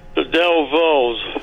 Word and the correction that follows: Dovells: Not saying it right. – That's all right. You know it's the Dovells, and I'll give it to you for that.